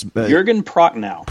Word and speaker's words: Prochnow: Jürgen Prochnow.